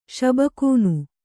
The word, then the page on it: ಷಬಕೂನು: kannada